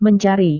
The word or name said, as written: mencari